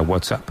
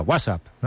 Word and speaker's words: WhatsApp: No, peor aún, con acento en la primera. WhatsApp, ¿no? – WhatsApp.